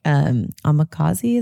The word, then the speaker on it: omakase: And all of a sudden he takes me to this sushi restaurant that's like omakase, is how you say it, where they like course it out.